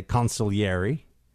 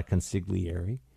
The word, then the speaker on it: consigliere: Some people in a nod to the Godfather and other mafia pop culture referred to him as a consigliere. As we all know, you don't pronounce the G, so it's not a consigliere.